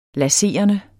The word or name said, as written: laserende